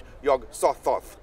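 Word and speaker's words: Yog-Sothoth: Uh, in, in, I believe he said it with the soft th. Yog Sothoth.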